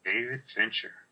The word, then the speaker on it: David Fincher: David Fincher.